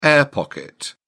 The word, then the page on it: air-pocket: British English